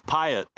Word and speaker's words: Pyatt: It's Pyatt, P-Y-A-T-T, yeah. Pyatt.